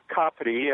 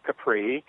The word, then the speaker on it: Capri: We spent a couple of days in Naples prior to the cruise and went over to the island of Capri, as they pronounce it, not Capri, for another day.